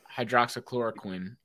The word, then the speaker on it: hydroxychloroquine: All right, and then he also hailed the drug hydroxychloroquine. Is that how you pronounce it? – Yeah. – Hydroxychloroquine as a potential treatment for COVID-19.